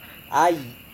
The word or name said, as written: ai